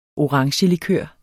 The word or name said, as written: orangelikør